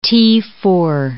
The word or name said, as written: T4